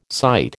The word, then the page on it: sight: American English